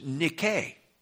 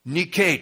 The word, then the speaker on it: nike: It's nike, spelled N-I-K-E. Does that ring a bell with anybody? We mispronounce it in the English. We call it Nike.